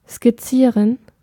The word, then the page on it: dessiner: France; [de.si.ne]